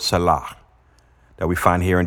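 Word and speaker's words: shalach (שָׁלַח): Now, this word, send out of her house, If you look it up in the Hebrew word, this word is spelled S-A-L-A-H. S-A-L-A-H, and it's pronounced like shalach.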